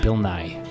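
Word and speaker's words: Nighy: It's subtle. It's like Bill Nye. – Not like the science guy, though. – No. Okay. The second syllable is like an extra breath. It's like Bill Nye.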